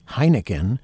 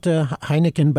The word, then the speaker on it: Heineken: No one remembered Johann David Heineken, and you wouldn't even say it without giggling a little bit, because it's, even though it's spelled differently, it's pronounced the same way as the beer.